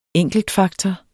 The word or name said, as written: ENKELTFAKTOR